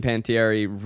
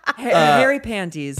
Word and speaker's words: Panettiere: Well, I would say this one has the perfect moment of Hayden Panettiere ripping ass. – we need a smash cut of all the wrong pronunciations. – Right. – Harry panties. – Pantaloni.